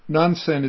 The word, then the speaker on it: Nansen: Nansen is, of course, the Japanese way of pronouncing the name. Nanchuan is the Chinese way.